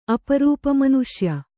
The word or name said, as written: ಅಪರೂಪಮನುಷ್ಯ